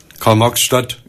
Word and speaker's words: Karl-Marx-Stadt: Ich hatte ja fast zehn Jahre Disco gemacht in Karl-Marx-Stadt.